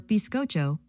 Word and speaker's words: bizcocho: Spanish sponge cake, or bizcocho, was created by Italian chefs in the 1400s. It also happens to rhyme with ocho, the Spanish word for eight.